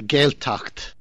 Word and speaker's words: Gaeltacht: It's called the Gaeltacht.